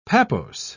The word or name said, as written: pappose